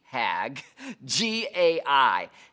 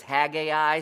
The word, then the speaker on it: Haggai: Let me spell it for you. H-A-G, Hag, G-A-I, Haggai. That's how I, you know, have always said it, is Haggai. Some people just say Haggai. Say it however you want, okay?